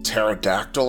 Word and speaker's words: pterodactyl: Is that like a pterodactyl or something? – Kind of the same idea, where the P is silent.